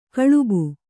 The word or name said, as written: ಕಲುಬಿ